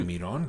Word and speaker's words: Mirón: de Mirón, lo dije bien.